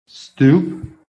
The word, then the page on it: STOOP: American English